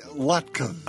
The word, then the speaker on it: latkes: Before our next call, Zorba, garlic and rosemary latkes.